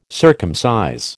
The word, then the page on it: circumcise: American English